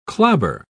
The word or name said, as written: clobber